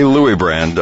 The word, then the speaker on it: Luibrand: And he is now consulting, actually working with Jessica Luibrand. Did I pronounce that right? – It's Luibrand, actually. – Okay, Luibrand. Okay, because I did it the Dutch way.